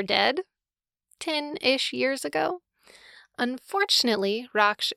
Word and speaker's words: Raksh: It's spelled R-A-K-S-H, and the one pronunciation guide I could find online pronounced it Rockshare, which I'm pretty sure isn't right. Raksh. I really want to call him Rakash, which is a name from Tamora Pierce. Let's focus. Raksh.